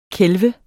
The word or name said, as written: kælve